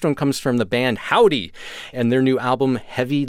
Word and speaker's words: Hovvdy: Hovvdy, spelled H-O-V-V-D-Y, because that's how bands do it now. Hovvdy.